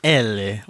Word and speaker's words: Eli: They do say it like Elle. Like they Elle, yeah, it's right. It's Swedish, yeah.